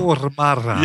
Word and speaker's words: Stormare: Stormare.